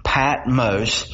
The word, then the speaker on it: Patmos: Some people say Patmos is really a long O, Patmos.